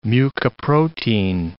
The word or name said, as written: mucoprotein